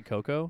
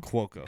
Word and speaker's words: Cuoco: You say Coco? – Cuoco. – Cuoco. – Yeah.